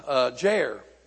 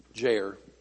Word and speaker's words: Jair: All right, Jair, like hair, Jair. Now, I was hoping that my resident Hebrew scholar would be here today to tell me if I'm pronouncing that correctly. It's right in English, but I don't know if it's right in Hebrew.